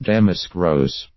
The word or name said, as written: damask rose